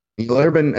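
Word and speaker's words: Neal Urban: Neil Urban, NEALURBAN.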